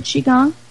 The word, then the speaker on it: Qigong: Did I pronounce that right, Qigong? Is that right? – You've got it.